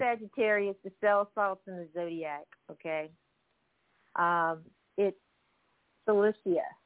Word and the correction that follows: silica: cell salt number 12, or maybe I'm pronouncing it wrong, silica, Cilicia? – It's silica, silica. – Okay, uh-huh. – It's silica, okay, silica.